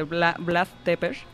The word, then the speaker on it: Vlad Tepes: Pues estamos hablando de Vlad Tepes, se pronuncia así, me parece, en el rumano, que lo conocemos más bien como Vlad el Empalador.